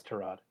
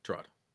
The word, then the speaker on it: Tyrod: Is it Tyrod? Can we get a name pronunciation check? – It's Tyrod. Tyrod or Tyrod? – He himself said it's Tyrod. – Okay, good. Okay. Tyrod.